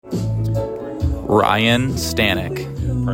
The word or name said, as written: Ryne Stanek